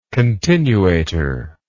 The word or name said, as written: continuator